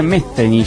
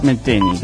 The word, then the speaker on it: Metheny: Parece que es la TH sería Metheny. Yo le digo Pat Metheny, qué sé yo. – Vos sabés que nuestro... – Sería Metheny.